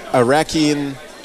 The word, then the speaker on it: Iraqi: You're from Iraq. – I am from Iraq, and I'm Chaldean, Christian Chaldean. – Christian Chaldean, and right now she's in a beautiful, beautiful, traditional Iraqi, did I say that right? – Yes, Iraqi top or Iraqi fustan.